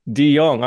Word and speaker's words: De Jong: It's De Jong. It's a silent J. – De Jong.